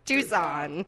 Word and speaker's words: Tucson: than once, you would, you know, that it's pronounced Tucson. Tucson.